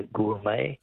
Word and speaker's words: gourmet: Be a gourmet. – Gourmet, yes. Correct. G-O-U-R-M-E-T.